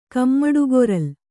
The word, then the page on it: ಕಮ್ಮಡುಗೊರಲ್: kannada